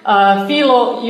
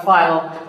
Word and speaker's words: Philo: But that's not all Philo does. Or Philo. We usually say Philo, but I'm more used to Hebrew pronunciation. Say Philo.